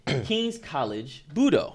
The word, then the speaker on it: King's College Budo: King's College Budo. Okay. Am I saying that right? – Yeah, you say that amazingly right.